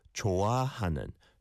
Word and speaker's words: 좋아하는: And I think the bit to improve in your sentence was 좋아하는. 좋아하는. 좋아. Is a 히읗 받침 and then an ah sound as a second syllable.